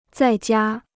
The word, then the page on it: 在家: zài jiā